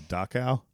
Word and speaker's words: Dachau: The Dachau? – Yes. So it wouldn't be a Princess Diaries episode without us not knowing how to pronounce something. – So the Dachau concentration camp.